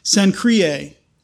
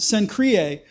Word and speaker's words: Cenchreae: I wrote down the pronunciation because I knew I'd say it wrong. Cenchreae. What is with the Latins and vowels? You know, they string a bunch of vowels together and they expect people like me to be able to pronounce it.